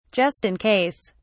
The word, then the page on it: just in case: British English